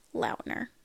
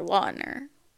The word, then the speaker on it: Lautner: And the last and probably most silly story that I have for you today is that Taylor Lautner announced that we have been pronouncing his name wrong all of this time. He says his name is actually Taylor Lautner, which sounds absolutely ridiculous after decades of saying Taylor Lautner, you know? And he said, quote, "It is my fault. Nobody knows how it's actually pronounced because I never corrected anybody for decades," unquote.